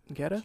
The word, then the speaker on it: geta: A Gita. – A Gita? – I don't know if it's pronounced like that. It's G-E-T-A. – Geta? – G-E-T-A.